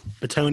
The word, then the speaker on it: Bitonio: Joel Bitonio, right? – Yeah, Bitonio.